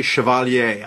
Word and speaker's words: Chevalier: And I pronounce it the French way, Chevalier. I don't know if it's Chevalier, like the way – you know, but think about Chevrolet. That's the way I'm going with it.